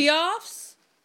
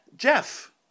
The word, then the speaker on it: Geoff: How do you say G-E-O-F-F? – Jeff.